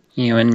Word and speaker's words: Ewan: How do you say his name? – Ewan McGregor. – But that sounds like it starts with a U when it says E. – That's a Scottish name.